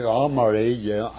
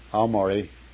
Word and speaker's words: Aomori: I went to Aomori. Aomori was headquarters, the Japanese Army headquarters. Aomori. – Aomori. How do you spell it? A-M-U-R-I? – O-M-O-R-Y.